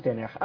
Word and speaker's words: تناخ: الكاف لما بتيجي في الآخر في العبرية بتنطق خاء، فسموه تناخ.